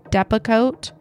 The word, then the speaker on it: Depakote: Am I saying that right? Depakote? – Yes, you are.